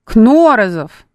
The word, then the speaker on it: Кнорозов: Ударение на первый слог. Так, где ударение? Кнорозов.